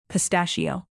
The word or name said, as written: pistachio